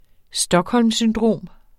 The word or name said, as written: stockholmsyndrom